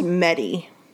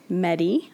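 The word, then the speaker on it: Métis: The book didn't really, it described Lucky as Metis, M-E-T-I-S. – I think it's pronounced Medi. – Medi, okay.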